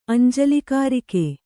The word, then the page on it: ಅಂಜಲಿಕಾರಿಕೆ: añjalikārike